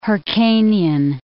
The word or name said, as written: Hyrcanian